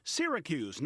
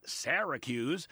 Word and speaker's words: Syracuse: The town in upstate New York is pronounced Syracuse, not Syracuse.